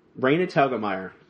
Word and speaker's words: Telgemeier: This week we're doing a duo of books, Smile and Drama by Raina Telgemeier, and that is how you say it.